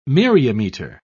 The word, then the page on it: myriameter: British English